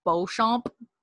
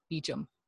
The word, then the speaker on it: Beauchamp: But it's like spelled like Beauchamp, but it's Beecham.